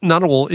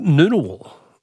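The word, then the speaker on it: Ngunnawal: Jackie says Nunawal is pronounced Noonawal.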